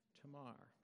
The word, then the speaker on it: Tamar: But I think actually Hebrew would give us a little bit more appropriate pronunciation of Tamar. Say it together. Tamar. Very good. Put that emphasis on that last syllable.